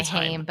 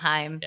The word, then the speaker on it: Haim: I never say it right. I always say Haim, but it's Haim.